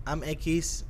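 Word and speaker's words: equis: I'm Akiz on Twitter and Instagram, EH_KEES. That's how you pronounce X in Spanish, just for the people that, what's Akiz mean?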